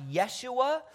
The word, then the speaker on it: Yeshua: Now, the name Jesus in Hebrew would be pronounced Yeshua, which could also be translated Joshua.